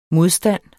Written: modstand

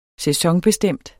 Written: sæsonbestemt

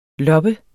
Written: loppe

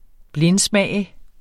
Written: blindsmage

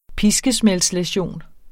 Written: piskesmældslæsion

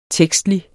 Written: tekstlig

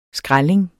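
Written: SKRÆLLING

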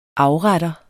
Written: afretter

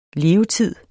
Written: levetid

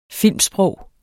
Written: filmsprog

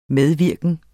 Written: medvirken

